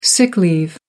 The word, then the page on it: sick leave: American English